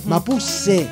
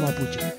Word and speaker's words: mapuche: Mapuse, no mapuche.